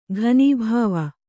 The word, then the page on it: ಘನೀಭಾವ: Kannada